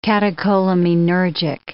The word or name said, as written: catecholaminergic